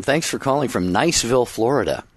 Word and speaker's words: Niceville: It's Niceville.